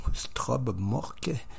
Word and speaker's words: Strobemørke: On aura dans un premier temps le groupe Troika, un extrait du 3e album qui va paraître à la milieu août, Strobmork. C'est du moins la prononciation à la québécoise.